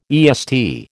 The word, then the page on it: EST: American English